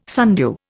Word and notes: sundew: Indian

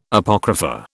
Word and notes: Apocrypha: British English

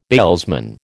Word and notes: bailsman: American English

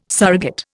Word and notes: surrogate: Indian

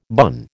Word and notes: bun: American English

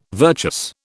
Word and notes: virtuous: British English

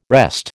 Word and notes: wrest: American English

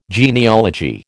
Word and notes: genealogy: American English

